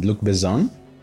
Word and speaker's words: Luc Besson: no, eh, digo, es, Luc Besson, pero es en francés es como que se pronuncia con Z, Luc Besson.